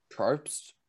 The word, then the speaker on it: Probst: Jeff Probst? – Yes, I think that's how you say his name. – Jeff Probst, he's the host of Survivor, so he wanted to be him.